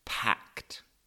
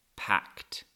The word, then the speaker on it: packed: And it is packed. Packed. P A C K E D. Remember the pronunciation? Like a t. Packed.